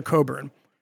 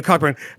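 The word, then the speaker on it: Cockburn: Like, when people I really disagree with who could write, Alexander Coburn, the most obnoxious. – You mispronounced his last name again. – Yeah, Coburn.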